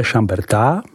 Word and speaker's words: Chambertin: Chambertin, vyslovujeme Chamberta, značí překladu Bertovo pole. Grand Cru v Chamberta jsou tedy následující. Chambertin, vyslovuji Chamberta, Chambertin, Clos de Beze, vyslovuji Chamberta Clos.